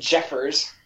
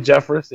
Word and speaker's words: Jeffress: And Jeffers. – Oh, instead of Jeffress, yeah. That was a good one. – That's what I said, Jeffress.